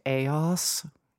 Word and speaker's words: Eos: It's pronounced Eos.